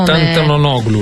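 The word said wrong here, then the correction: Tentoglou: Miltiadis Tentoglu.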